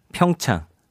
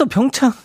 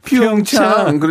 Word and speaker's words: 평창: 아니, 그분이 읽을 때 영어 발음으로 피운, 피운창. 그러잖아요. 예.